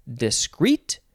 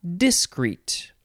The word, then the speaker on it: discreet: You can emphasize either syllable, discreet or discreet.